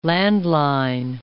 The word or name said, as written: landline